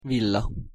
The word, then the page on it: villa: Hungarian